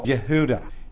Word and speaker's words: Yehuda: So there were 12 tribes, remember, and Judah, Yehuda, because there are no J's in Hebrew. They're all Y's, but when the Germans came along, their J's are pronounced like a Y,